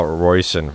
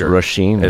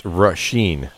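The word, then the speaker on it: Róisín: We're going to a new track from, we've been calling this gal Royson forever, right? – It's Roisin.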